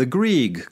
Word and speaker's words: Grieg: Is that, am I saying that right? Grieg? – The Grieg Club.